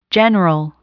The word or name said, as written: general